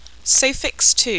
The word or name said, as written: sophix ii